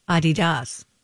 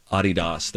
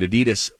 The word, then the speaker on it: Adidas: The appropriate way to pronounce it. We learned that a few years ago. It's a German family, Adidas. That's how you pronounce the name. We Americans decided, nah, we'll change it and we'll make it Adidas.